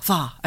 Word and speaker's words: Hvar: Have I said that correctly? Hvar? – Hvar.